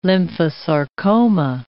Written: lymphosarcoma

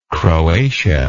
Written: croatia

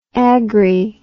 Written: agri

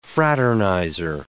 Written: fraternizer

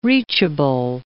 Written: reachable

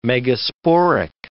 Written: megasporic

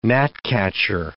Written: gnatcatcher

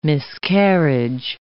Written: miscarriage

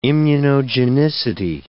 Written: immunogenicity

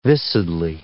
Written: viscidly

